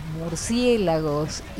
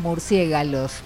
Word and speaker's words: murciélago: Después, nos van a contar sobre Drácula y qué les pasa con los murciélagos y ese tipo de cosas. Yo les voy a preguntar si lo pronuncian bien la palabra murciélagos, porque algunos dicen murciégalos. Ahí me salió, pero no, es murciélago.